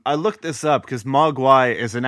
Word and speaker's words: Mogwai: Mogwai? Am I saying it right again? – Yes.